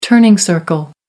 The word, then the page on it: turning circle: American English